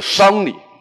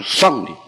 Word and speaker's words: 丧礼: 嗯，我这几年我很少看的一部，我可能我《琅琊榜》我看了一遍，《琅琊榜》其他我觉得我都不挑它，但是它里面呢，它把所有的这个，比如说太皇太后的丧丧礼呀，在里面全部读成了丧，这是错误的，是丧礼，不是丧礼，是丧服，不是丧服，因为我觉得我们说文化产品，尤其这种影响很大的这种文化产品。